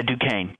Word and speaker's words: Duquesne: It's spelled funny, but it's pronounced. – Yes. Yeah, Duquesne. Duquesne University, yeah.